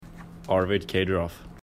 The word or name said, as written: Caderoth